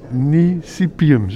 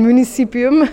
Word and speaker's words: municipium: Het is een, je moet even kijken, een municipium. Zeg ik dat goed? – Ik denk het wel, ja. Een municipium.